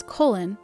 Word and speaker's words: Colin: The late Colin Powell adopted the pronunciation of his name as Colin after hearing how Colin Kelly's name was pronounced.